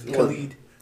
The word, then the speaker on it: Khaled: That's Khalid. That's how it's actually pronounced.